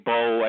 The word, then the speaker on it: Bouye: But, okay, the kid A.J. Bowe, I guess is how you say his name, B-O-U-Y-E. He's from the Houston Texans.